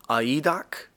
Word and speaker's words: aidos: The word modesty in the Greek is aidos, but it's spelled A-I-D-O-S.